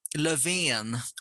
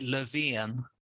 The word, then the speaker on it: Löfven: Well, in my head, I was always thinking, Lothin, like a loaf of bread. But yeah, how would you say it? – Well, Löfven, we say Löfven.